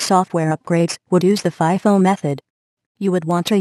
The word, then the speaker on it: FIFO: FIFO, First In, First Out, pronounced FIFO.